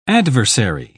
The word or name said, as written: adversary